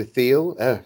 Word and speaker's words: Thiel: That also feeds into Peter Thiel.